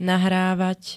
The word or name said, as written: nahrávať